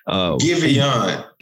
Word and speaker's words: Giveon: No, not Giovanni. Javon. I be screwing his name up all the time. I want to call him different stuff. Javon, G-I-V-E-O-N. – Giveon. – Giveon. Okay. My bad.